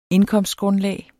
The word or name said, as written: indkomstgrundlag